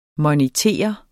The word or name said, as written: MONITORER